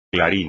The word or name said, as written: clarín